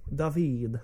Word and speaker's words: David: There's no way that's not pronounced David. – No, it's David.